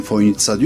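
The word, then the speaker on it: Fojnica: Ama çözemediğim şey, telaffuz olarak ben Foynitsa diyorum, öyle duydum. Fakat gelen misafirlerin hepsi o C'yi S olduğunu bilmiyor, TS olduğunu bilmiyor ve Foynica'ya işte gelmekten şeref duydum gibi şeyler zikrediyor.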